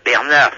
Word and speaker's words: Bernard: Ben Bernard, si, c'est mon prénom, mais ça s'écrit pas comme vous me le prononcez. Ça s'écrit B-E-R-N-A-R-D.